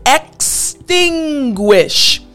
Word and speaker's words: extinguish: Now, what I want to help you pronounce, this word, the beginning portion, exting, that part is not too difficult. But the end, guish, guish. This is the part that I want to help you pronounce properly like a native English speaker. The G-U-I-S-H, extinguish.